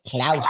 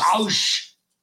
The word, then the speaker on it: Klaus: Klaus. – Klaus.